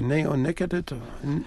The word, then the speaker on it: neonicotinoids: So people want to, I don't know if I pronounce that, neonicotinoids? – Neonicotinoids. – There we go. That's the right way to pronounce it.